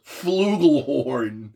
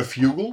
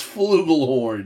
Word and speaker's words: flugelhorn: First of all, it's pronounced flugelhorn. – Not a fugle? – No, not a fugle. – Are you sure about that? – It's flugelhorn.